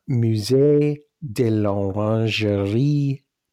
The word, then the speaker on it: Musée de l'Orangerie: And I would pronounce the name of the museum as Musée de l'Orangerie Paris. – There you go.